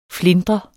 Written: flintrende

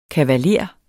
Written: kavaler

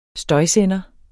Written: støjsender

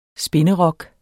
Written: spinderok

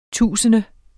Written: tusinde

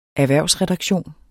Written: erhvervsredaktion

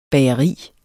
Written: bageri

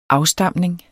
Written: afstamning

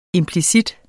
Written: implicit

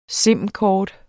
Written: sim-kort

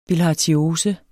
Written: bilharziose

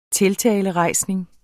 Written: tiltalerejsning

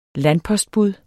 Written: landpostbud

